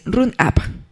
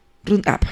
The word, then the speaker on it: Roundup: Lo estoy pronunciando mal, es Roundup. Roundup, sí.